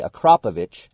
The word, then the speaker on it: Akrapovic: I felt like shit, but he made me say Akrapovic about 50 times before we went to go eat lunch. So thank you for teaching me the right way, but that is how you say it.